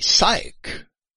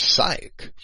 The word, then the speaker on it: sych: Nope, it's just Shin Yod, and then the Heth character, which is the hard C-H. So that would be psych, is how I would transliterate and pronounce that. S-Y-C-H, psych.